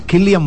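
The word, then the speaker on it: Cillian: Ah, irlandés, y por eso se pronuncia Ki, no Si. Se escribe con C, pero suena Ki. Killian Murphy.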